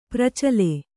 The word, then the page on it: ಪ್ರಚಲ: kannada